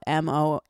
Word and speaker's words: Mondeuse: It's spelled Mondeuse.